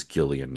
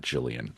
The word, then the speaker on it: Gillian: All right, so Gillian Flynn, and it is a hard G. I actually did look that up. It is Gillian, not Jillian.